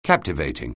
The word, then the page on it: captivating: anglais